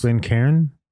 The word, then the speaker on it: Glencairn: And we're drinking it out of the Glencairn glass. Is that how to pronounce that? – The Glencairn glass? – Glencairn? – Yeah.